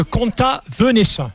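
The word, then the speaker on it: Comtat Venaissin: puisque vous signez cette nouveauté qui est le compte Venaissin, comment on prononce ? – C'est le Conta Venaissin.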